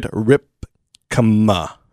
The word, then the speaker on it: Rypkema: I'm here with Jared Rypkema. Am I saying that right? – Rypkema, correct. – Rypkema, thank you. Rypkema.